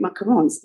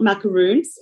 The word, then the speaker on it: macaron: how to make macarons. In France, you've got to learn how to make macarons. First of all, you have to learn how to say it properly. It's not macaroons, it's macaron.